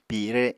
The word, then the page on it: pire: Apatani